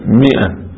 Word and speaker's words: مائة: ناس يقولها ثلاثمائة، يقولوا ماء، غلط، هي مئة، تكتب ماء ولكن تنطق مئة، لأن الأول ماكو مثلا نقط منه فيه ومئة، فصار يكتبوها ماء وإلا هي مئة، هذا من أخطاء الشارع.